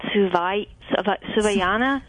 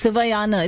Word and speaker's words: Suvayana: I'm going to say it's called Suvayana. – That's perfectly right. – What is its meaning, and how do I pronounce it? – No, you pronounced it beautifully. It's Suvayana. It's very phonetic.